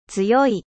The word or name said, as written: fort